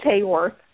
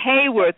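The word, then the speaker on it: Haworth: Did I get it right? – It's Hawthorne. Close. – Hawthorne, excuse me.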